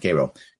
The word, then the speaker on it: Cairo: Cody says, when you were at Old Factory the other day in Cairo, that's not pronounced Cairo, it's pronounced Cairo, New York, you brought up a lot of great points about how we can reassess the tax budget.